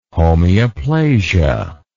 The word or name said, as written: homeoplasia